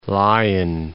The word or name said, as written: Lion, Gulf of